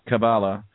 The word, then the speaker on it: Cabala: you know, delivered the Kalaba, or it's spelled C-A-B-A-L-A. – Kabbalah to humankind.